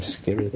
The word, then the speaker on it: Skirrid: This is uh, in regards to the Skidmore, Skidrid. How do you say that? – Skidrid. Skidrid. – Skidrid in. Skidrid in. – Yes, I've been to the Skirrid Inn.